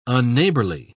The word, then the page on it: unneighbourly: British English